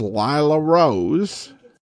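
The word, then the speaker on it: Lilla Rose: I seem to hear you saying Lila or Lila, but you seem to spell it L-I-L-L-A, Lila, which is it? Well, it is L-I-L-L-A, but it's pronounced Lila Rose, or that's at least how I've heard it.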